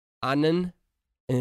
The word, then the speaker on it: Ananda: Her last name is spelled A-N-A-N, so Annan. Anand and then DA, so Ananda.